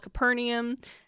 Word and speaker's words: Capernaum: And it looks like it's pronounced Capernaum, but I looked it up yesterday just to make sure I was saying it right. And apparently it's called Capernaum.